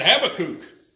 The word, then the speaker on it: Habakkuk: Like Habakkuk instead of Habakkuk. I preached in a church one time and I made reference, of all the times I'd ever reference Habakkuk, I referenced it in a church where they all looked at me and the one fellow said, we would pronounce that Habakkuk. And I thought he was joking. It turned out he was serious.